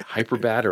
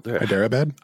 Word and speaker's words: Hyderabad: Like Hyderabad, or like, wow, it's Hyderabad, dude.